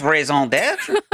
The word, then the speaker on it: raison d'être: Oh man, it's raison d'être.